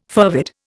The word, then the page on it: fervid: Indian